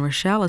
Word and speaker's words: Rochelle: My name is Rita, R-I-T-A, and Rochelle is spelled R-O-C-H-E-L-L-E.